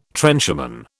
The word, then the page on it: trencherman: British English